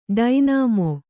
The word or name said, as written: ಡೈನಾಮೊ